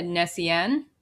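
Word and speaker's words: Gnossienne: Well, I've heard, and I think this is how it's said, Nessien. – That's what I thought it was, yeah. – Silent Nessien. G, right? – Yeah, I think so.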